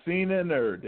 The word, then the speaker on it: Cena Nerd: It rolls off the tongue. Cena Nerd. Yep, you got it, man.